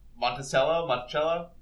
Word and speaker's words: Monticello: Not to be confused with Voice of Montcello, which has a lot of slavery. – Monticello? – I forgot the middle syllable.